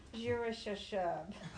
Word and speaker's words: Shear-Jashub: His name is Shear-Jashub. – Shear-Jashub. – Pronounce it for us. – Shear-Jashub. Just like he said.